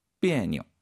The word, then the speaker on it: 别扭: 비에뇨. – 비에뇨. 역시 그 니오자의 발음 경성으로 읽는다는 거 가볍게. 그래서 비에뇨.